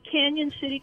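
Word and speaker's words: Cañon City: It's spelled Canon, but it has like, so you think it's Canon, but it has the little tilde over that one N.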